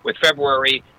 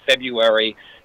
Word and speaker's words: February: Just like February, it's not February. The R is said kind of quickly, so it might come across as February, but the R should be pronounced.